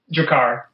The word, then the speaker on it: Drakkar: They've got a new album called Alignments, which released via, is it Drakkar? Drakkar? How do I say that? – Drakkar.